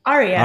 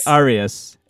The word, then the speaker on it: Arias: Arias. Ari- Arias. – like an aria.